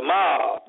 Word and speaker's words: Moab: But they pronounce that M-O-A-B-M-A-B-Mob. Sound, make it sound like the prince of the mob, the masses.